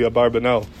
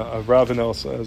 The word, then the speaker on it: Abarbanel: So machleg is how to say his name, if it's Abarbanel or Avravanel, is that the nusach? And I'm going to go with Minhag Olam as Abarbanel, but they, that's a debate how to pronounce it.